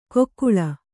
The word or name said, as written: ಕೊಕ್ಕುಳ